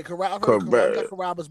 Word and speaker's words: Carrabba's: And I hope I say this right, but I think it's called Carrabba's. – Yeah, Carrabba's back home.